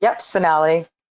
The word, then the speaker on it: Sonali: It's Sonali.